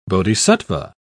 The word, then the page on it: bodhisattva: British English